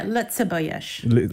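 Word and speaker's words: Lëtzebuergesch: Luxembourgish. – Lovely, wrong. Yeah. Lutzebuyish, they call it. – Oh, Lutzebuyish. – Okay. There's a G in there that's not pronounced.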